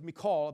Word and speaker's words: Michal: Now, as the ark of the Lord came into the city of David, Michal, which looks like Michael, but it's Michal, I believe is how it's pronounced,